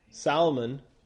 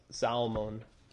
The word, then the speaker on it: Salmon: And, of course, the source for her marrying Salmon, which is pronounced Salmon. Salmon. Like Salmon. Salmon. Salmon. Salmon. But it looks like it's pronounced salmon.